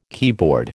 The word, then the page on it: keyboard: American English